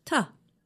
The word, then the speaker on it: to: When we say it quickly, the word to is pronounced ta.